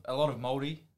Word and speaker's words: Māori: A lot of Māori, that's how you say it, boys. – Māori.